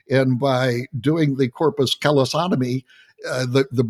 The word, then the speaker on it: callosotomy: You've underwent a corpus callosotomy operation. I mm-hmm. think, did I pronounce that right? Callosotomy? – That's right.